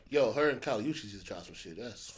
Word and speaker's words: Kali Uchis: Kali Uchis.